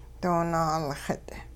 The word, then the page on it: doo naalxit': hupa